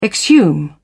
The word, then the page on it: exhume: British English